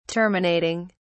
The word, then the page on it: terminating: British English